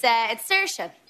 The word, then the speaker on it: Saoirse: It's Saoirse.